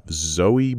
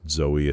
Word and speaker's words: Zoe: And we have Zoe Bell as herself. I've also heard it said Zoe Bell. I don't know. I pronounced Zoe, especially with it's got the two dots over the E as Zoe.